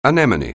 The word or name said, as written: anemone